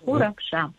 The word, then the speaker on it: Уракша: Борис, вы абсолютно правильно произнесли. Уракша.